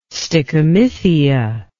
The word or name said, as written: stichomythia